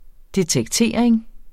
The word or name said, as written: detektering